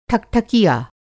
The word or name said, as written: ठकठकिया